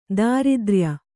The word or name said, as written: ದಾರಿದ್ರ್ಯ